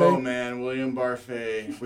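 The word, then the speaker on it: Barfée: It's Barfy. Ooh. – Barfy. – One of the jokes during the play is they keep saying his name wrong. So okay. They say Barfy. But it's French. It's Barfay.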